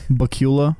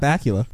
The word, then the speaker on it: Bakula: Bakula? – No, it's Scott Bakula. – Bakula. – Okay, Bakula. That's his traditional.